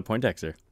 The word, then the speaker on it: Poindexter: I'm joined today by Taylor Poindexter.